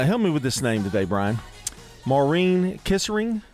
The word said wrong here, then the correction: Kesselring: Am I saying that correctly? – Kisserling, maybe. There's an L in there. – Kesselring. – There you go.